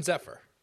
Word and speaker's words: Zephyr: The Golden Zephyr.